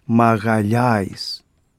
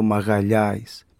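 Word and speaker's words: Magalhães: Ε, Μαρ Πασίφικο τον έλεγαν ήδη από το 1520 που τον διέσχισε ο Φερνάο Ντε Μαγαλιάης τι σπουδαίο αυτό ο Μαγαλιάης. Ποιος νομίζει το τι είναι. Έτσι είναι η σωστή πορτογαλική προφορά του Μαγκελάνου.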